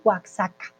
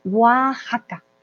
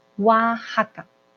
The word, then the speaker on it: Oaxaca: Miren que Oaxaca, aquí tiene una X, nadie dice Oaxaca, por favor, ¿vale? Oaxaca, esa X se pronuncia como una J, como en México, ¿vale? Oaxaca. DUA, sí está súper caro el vuelo de Colombia a Argentina, entonces fíjate cuándo es el de la India ya.